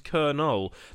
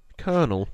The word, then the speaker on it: colonel: The pronunciation is Colonel. The reason it's a strange word for foreigners is because it lacks an R, despite using that sound when spoken. The second O is silent as well, which adds to the confusion.